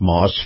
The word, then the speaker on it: Maus: But this portion of the program brought to you by our friends at Moss Family Automotive. That's spelled M-A-U-S.